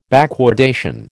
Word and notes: backwardation: American English

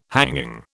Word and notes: hanging: British English

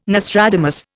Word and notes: Nostradamus: Indian

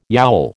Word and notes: yowl: American English

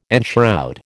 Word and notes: enshroud: American English

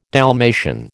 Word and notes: Dalmatian: American English

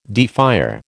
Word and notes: defier: American English

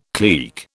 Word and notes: clique: British English

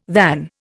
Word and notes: than: Indian

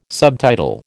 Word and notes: subtitle: American English

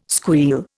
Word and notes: squeal: Indian

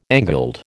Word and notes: angled: American English

